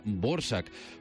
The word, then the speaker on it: Dvořák: Espero no haberme equivocado en la selección de la serenata para cuerdas, primer movimiento del compositor checo Antonín, que yo siempre había dicho Dvořák, no sé por qué, pero me han soplado hoy que en realidad se pronuncia Dvořák.